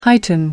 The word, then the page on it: heighten: American English